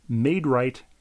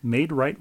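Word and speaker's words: Maid-Rite: And my friend Dan in Milwaukee texted me about Made Right restaurants. Have you heard of Made Right restaurants? M-A-I-D hyphen R-I-T-E? – Of course they spell it R-I-T-E.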